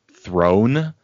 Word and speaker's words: Theron: And then, yeah, she also explained that while her name is spelled Theron, it's Throne.